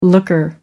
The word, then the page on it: looker: American English